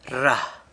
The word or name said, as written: rra